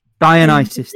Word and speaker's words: Dionysus: How do you pronounce the name of the Greek god of wine? Is it Dionysus? – Dionysus, there you go.